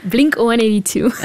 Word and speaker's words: Blink-182: We hadden zo net Story of a Lonely Guy van Blink-182, zeg ik dat goed? – Blink-182. – Ah, oké. Magali de Reu, het is jouw favoriete band, hè? – Ja, van alle...